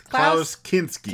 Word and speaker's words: Klaus Kinski: It's hard to say Klaus. See, I want to say it like weird. – It's Klaus Kinski.